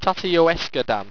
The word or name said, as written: tatteyoesqedan